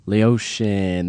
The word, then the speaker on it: Laotian: Laotian. That's how you say it.